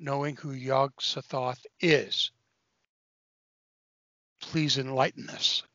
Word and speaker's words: Yog-Sothoth: Okay, so you've got to help us out here by helping me with the title, which is Tales of Yog-Sothoth.